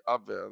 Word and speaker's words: Abwehr: Abwehr. Abwehr. – A-B-W-E-H-R.